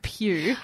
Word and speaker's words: Pugh: You in the office today were like, Florence Pugh? And I was like, no, Pugh. – No, I just mispronounced it.